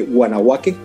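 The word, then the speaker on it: Wanawake: ¿Puedes explicarnos cómo nace Guanahuaque o cómo se pronuncia? Que no sé si está bien pronunciado. – Está perfectamente pronunciado. Guanahuaque significa mujeres en la lengua suajili.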